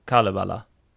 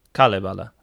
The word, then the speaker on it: Kalevala: And then we have Kullervo is the name of the character from the Finnish epic, and Kalevala. Kalevala. I think that's I'm saying that right. – Yeah, so, I think you are.